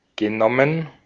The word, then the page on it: genommen: /gəˈnɔmən/